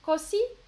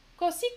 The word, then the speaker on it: così: Ah oui, moi j'ai mis l'accent sur le O. Alors l'accent est sur le I, l'accent tonique. Così Cosi, cosi.